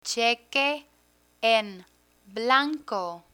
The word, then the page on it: cheque en blanco: spanish